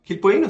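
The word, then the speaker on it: Quilpueíno: Vamos a comenzar a hacer la primera revisión y esto va a quedar a cargo del gran Quilpueino, ¿cómo se dice? Quilpoino, sí, señor.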